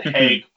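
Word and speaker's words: Haigh: I watched 45 Years, directed by Andrew High. Is that how you pronounce his last name? – I would have said Haig, but... – It's Haig? Okay.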